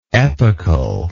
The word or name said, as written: epochally